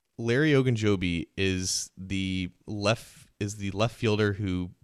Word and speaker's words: Ogunjobi: The last defensive lineman I want to touch on is Larry Ogunjobi. Probably butchered that last name, but is he someone that the Raiders need? – Ogunjobi. – All right. Eventually I'll get it right.